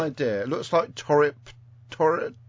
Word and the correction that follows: Turritopsis: No, turritopsis.